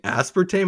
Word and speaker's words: aspartame: Just like aluminium and aluminum is aspartame. – Oh, why couldn't I think of that in the moment? Oh, I'm sorry. That's the European pronunciation.